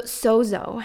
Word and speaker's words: sozo: So sozo, have you ever heard that strange word before, sozo? It's spelled S-O-Z-O, and it's actually Greek for wholeness, restoration to health, saved.